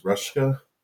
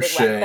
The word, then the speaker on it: Ruscha: The gentleman, I believe, is it pronounced Ruschka? – Ed Ruscha. It's a complicated Ruscha, one, but it's Ruscha, yeah.